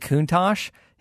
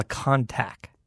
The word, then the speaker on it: Countach: And he's Canadian, and if I recall on his show, the one thing that always was weird to me is the Lamborghini Countach, he would call a contact. – Mm. – Like, if you think of how it's spelled.